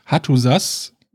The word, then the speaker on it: Hattusa: Also, ja, leicht andere Betonung, aber eben nicht Hattusha, sondern Hattusa.